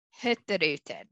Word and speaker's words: Hurtigruten: And the Norwegians so eloquently pronounce it Hurtigruten. So the mm-hmm. G is technically silent.